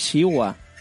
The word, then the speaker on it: Shiva: Es que no es Shiva, es Shiwa. – Depende de cómo lo pronuncies.